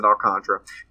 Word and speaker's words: Alcantara: Sandy Alcantara, or Alcantara, I think they pronounce it Alcantara.